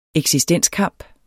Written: eksistenskamp

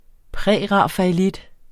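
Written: Prærafaelit

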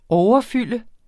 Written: overfylde

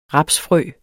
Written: rapsfrø

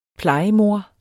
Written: plejemor

